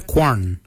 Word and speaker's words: gwan: Kwalak is spelled G-W-E-L-Y-A-G. Kwalak. Kwalak is your hips. Your backside is your quarn. Your quarn is spelled G-W-A-N.